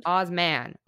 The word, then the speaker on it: Osman: So if you're looking for something a little nice, but also from the perspective of, I mean, Richard Osmond, obviously not that rich. I don't want to say it wrong. Osmond? Osman. Osman. – Oh, Osman. Okay.